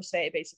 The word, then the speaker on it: Saie: I love Say Beauty. It's spelled S-A-I-E.